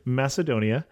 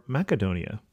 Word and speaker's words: Macedonia: and then I'm going to say it right, Macedonia or Macedonia.